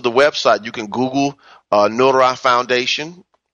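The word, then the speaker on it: Noohra: That's N-O-O-H-R-A. It's really pronounced Nuhra, but it's too hard for people to say, so we just shorten it to Nora. But it's not spelled like the name Nora. It's N-O-O-H-R-A, Nuhra, and it means light. That's the Aramaic word for light.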